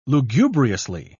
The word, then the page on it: lugubriously: British English